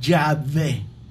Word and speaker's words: Yahvé: Y muchos estudiosos se han dado cuenta que los samaritanos siempre han pronunciado el nombre de Dios, y el nombre de Dios lo pronuncian Yahvé.